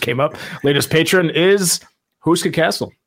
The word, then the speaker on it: Houska: And of course, the reason we're all here today is the Huska Castle, which is... – Are you going to pronounce it right once? Are you just going to say it properly one time? – Huska.